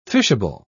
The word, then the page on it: fishable: British English